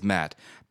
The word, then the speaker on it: Matt: I'll tell them my name is Matt. People spell it M-A-T.